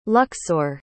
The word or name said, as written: luxor